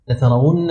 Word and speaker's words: لترون: بعض الناس ما يستطيع ينطقها هنا لأن الواو مضمومة إما أن يهمز وإما أن يغير فلعلكم تتدربون على النطق الصحيح نسأل فقط اقراها لترون